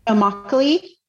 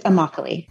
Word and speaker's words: Immokalee: Is it Immokalee? Is that the right way to say it? – So Immokalee. It's Immokalee. – Immokalee.